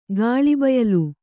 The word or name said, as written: ಗಾಳಿಬಯಲು